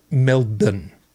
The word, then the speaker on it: Melbourne: It's not Melbourne because that's the way that it's spelled. It's Melbourne because that's the way that we say it.